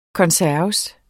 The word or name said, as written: konserves